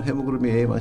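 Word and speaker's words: HbA1c: よく健康診断とかで出される血糖値とかヘモグロビン A1C とかっていうのもあると思うんですが、この違いについても教えていただけますか。 – ヘモグロビン A1C ってのは非常に大事なんですけど、まあこれは英語で書いてあるんでね、ちょっと HB の AHC って書いてあるんですけど、これもヘモグロビン A1C って読むんですけども、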